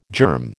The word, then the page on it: germ: American English